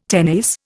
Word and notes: tenace: Indian

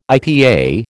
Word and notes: IPA: American English

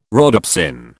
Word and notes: rhodopsin: British English

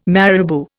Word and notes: marabou: Indian

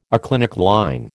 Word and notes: aclinic line: American English

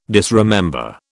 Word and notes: disremember: British English

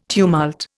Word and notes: tumult: Indian

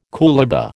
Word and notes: coolibah: American English

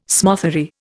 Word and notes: smothery: Indian

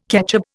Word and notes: ketchup: Indian

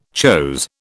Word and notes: chose: British English

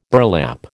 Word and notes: burlap: American English

